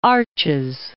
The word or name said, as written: Arches National Park